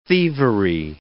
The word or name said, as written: thievery